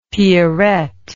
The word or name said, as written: pierrette